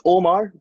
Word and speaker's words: Umar: It's Omar, but you know.